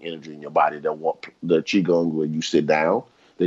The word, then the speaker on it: Qigong: It's pronounced you spell it Q I G O N G, but you pronounce it Qigong. Q I is Qi in Chinese, meaning energy.